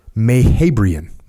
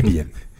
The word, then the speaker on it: Mehrabian: Totally murdered that one. – You're not Persian. – I am not. – You'd know how to say that if you were Persian. I don't know how to say it either. – Exactly. Mehrabian. Okay, we'll go with it.